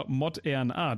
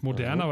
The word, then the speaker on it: Moderna: Sie schreiben Moderna und ihre, oder Mod-RNA, das ist ja auch Maut-RNA, ist eigentlich die richtige Betonung.